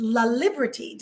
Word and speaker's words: LaLiberte: Today, I have the honor of having this fabulous guest that's joining me, Aimee, and it's LaLiberte.